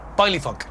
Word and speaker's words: baile funk: All right, it's me pronouncing a Brazilian word really wrong to the point that you don't know what I'm talking about, but ballet funk. – Baile funk.